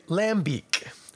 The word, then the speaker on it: lambic: But we see L-A-M-B-I-C and we go lambic. So I will not judge you if you pronounce it lambic. I might tease you depending on the context if you actually say it properly, because hey, that's just an affectation now. Lambic.